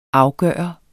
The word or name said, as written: afgjort